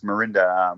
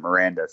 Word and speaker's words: Mirinda: They, uh, you know, it's Mirinda, um, but they they just see Mirinda and pronounce it Miranda.